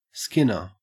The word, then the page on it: skinner: Australian English